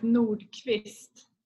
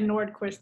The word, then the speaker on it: Nordqvist: You pronounce it Quist. – Yeah, back home it's Nordqvist, but I said Nordqvist over here. It's just easier for everyone.